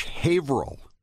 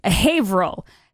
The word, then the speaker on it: Haverhill: Silent H. Haverhill. – Haverhill.